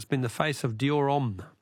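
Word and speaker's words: Homme: Don't pronounce the H in om. Oh, so it's Dior Om.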